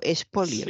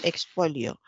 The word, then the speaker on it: expolio: Lo que pasa es que como casi nadie pronuncia bien la X, entre que ella dijo espolio polio y no expolio.